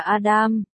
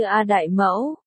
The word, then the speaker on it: Adam: Mỗi lần tôi gọi gã là Á Đương, gã đều sửa lại là Adam. Nhưng cái âm Adam này tôi phát âm không tốt, đọc lên nghe như A Đại Mẫu.